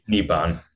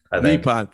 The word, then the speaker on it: Nippon: Pitching in the, am I saying this right? Nippon Professional Baseball League. I should know that. It's the 16th Nippon. – Nippon. Thank you.